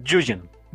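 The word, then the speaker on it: Nguyen: Se pronuncia Yugen. Dustin Yugen, ok, ya aprendí con una muy ligera N al principio, Yugen.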